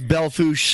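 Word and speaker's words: Belle Fourche: That's Belfouche, South Dakota. That's one of the stations that comes on in the second hour. That's spelled Bellfouche.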